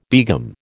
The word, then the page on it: begum: American English